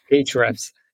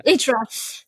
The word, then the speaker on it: Ahrefs: Some other ones that I use, for example, are Ubersuggest, and I don't actually know how to pronounce it, but Ahref. How would you pronounce that? It's like the Ahrefs. Yeah. – Ahrefs. So bad.